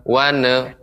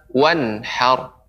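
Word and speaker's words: وانحر: Kemudian nun wanhar tidak boleh memantul wanhar wane wane akan tapi harus jelas wanhar wanhar Demikian Ibu, silakan dibaca. Fasalli. – Fasalli li rabbika wanhar.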